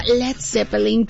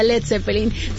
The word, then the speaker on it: Led Zeppelin: Peter Grant, su manager, sugirió quitar la A y evitar, no, ahora sí que Estados Unidos lo pronunciaran como Lead, y así nació Led Zeppelin.